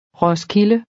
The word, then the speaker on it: Roskilde: Roskilde.